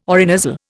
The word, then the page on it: orinasal: Indian